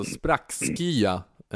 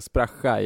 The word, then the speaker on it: Spraxkya: Det är alltså sprackskya som det uttalas på riktigt svenska. Spraxa är då dialektalt och det är S-P-R-A-X-K-Y-A. Sprackskya.